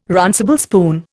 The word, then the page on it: runcible spoon: Indian